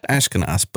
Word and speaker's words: Ashkenas: Gosh, it's been so long since he actually began as Jeremy Ashinkus, since we did not have pronounced his last name. And then we learned it's Ashkenaz.